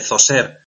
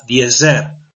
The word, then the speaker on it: Djoser: Cómo cambia de Zoser a Djoser o Djoser, depende de la pronunciación y de la transcripción que hagamos.